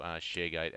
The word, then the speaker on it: ShareGate: And again, I'll again point people to ShareGate as a great option to do the migration to make it easy as drag and drop. Is that the right way to say it? – Yeah, absolutely.